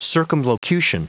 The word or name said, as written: circumlocution